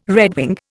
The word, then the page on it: redwing: Indian